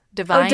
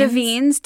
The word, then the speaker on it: Davines: Davines? – Divines? Oh, Davines. D-A-V-I-N-E-S.